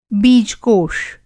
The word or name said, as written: बीजकोष